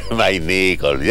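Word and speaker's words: Mayne-Nicholls: Ya, aquí estamos, el exalcalde de Chillán, expresidente ñublense, exdirigente del fútbol chileno, en la directiva que presidió Harold, se pronuncia Maynicol, ya.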